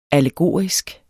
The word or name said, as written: allegorisk